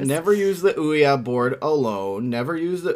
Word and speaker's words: Ouya: Why are you saying Ouya? – That's how you pronounce it.